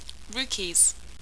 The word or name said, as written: Rukis